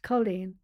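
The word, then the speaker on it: cailín: Now, Colleen is Irish and it's spelled C-A-I-L-I-N. It's Colleen.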